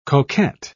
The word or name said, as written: coquet